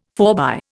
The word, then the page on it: forbye: Indian